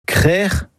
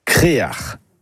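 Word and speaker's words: Krec'h: Créer ou Créar. Créar, c'est une prononciation plus du Nord Finistère, mais on la trouve parfois ailleurs.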